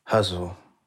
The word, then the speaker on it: ghazal: Do you mind reading a passage from Guzzle? – Sure, I'll read a few couplets. – And I'm pronouncing it that way, but it's spelled G-H-A-Z-A-L. – Yes, it's sort of more guttural. Huzzle, or like huzzah.